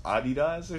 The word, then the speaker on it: Adidas: On some more, like, hey, I just found out it's like pronounced Adidas or some shit. – Well, that's been like that. – I didn't know that. I didn't know we were like pronouncing or like whatever. – Well, but that's like the I guess because it's a what is it, German? – Yeah. – It's German, yeah.